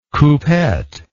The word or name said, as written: coupette